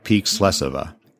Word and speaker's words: Slesova: Anna also informed me that we've been mispronouncing the name throughout this interview. It should be something like Peak Slesova.